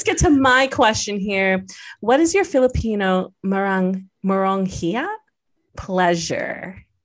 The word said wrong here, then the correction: meronghiya: Meronghiya. – Did I say that right? – Instead of walanghiya, it's meronghiya.